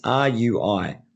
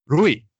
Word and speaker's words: Rui: So how do you pronounce the first name? It's R U I. Rui.